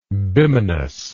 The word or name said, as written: bimanous